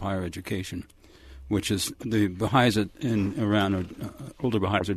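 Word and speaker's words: Bahá'ís: Is that how it's pronounced? Baha'is? – Baha'is. Mm-hmm.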